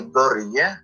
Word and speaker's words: Börje: uh, in Swedish, we say börje, don't but it's very difficult. So in market, they used to call me "borg."